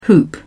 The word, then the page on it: hoop: British English